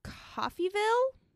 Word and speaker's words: Coffeyville: They stop in Coffeyville, is what it looks like. Okay. It's how you pronounce it.